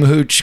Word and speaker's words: Hooch: Because I just assume Hooch, because it rhymes with pooch.